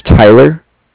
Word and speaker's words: Tyler: My name is Tyler.